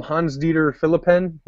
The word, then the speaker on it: Hans-Dieter Philippen: Hans-Dieter Philippen, which I'm probably pronouncing that wrong, from Germany. – Actually, I think that's right on the nose, man.